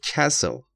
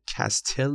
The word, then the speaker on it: castle: توی مثال دوم آخر جمله castle، castle، کاستل نمیگیم، اون تیش تلفظ نمیشه، تلفظ امریکنش میشه castle، تلفظ بریتیش میشه castle، castle، castle یا castle، هر دوش درسته.